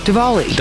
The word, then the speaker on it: Diwali: I was saying Diwali, so I'm the wrong guy to ask, that's for sure. – Well, it's pronounced... I mean, it's spelled Diwali, so it makes sense. – Pronounced Diwali, though? – Diwali, W yes. makes a V sound.